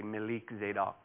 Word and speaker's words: Melchizedek: He is now and forever our royal priesthood, like Melchizedek is how it's said, but it is actually Melik Zedek.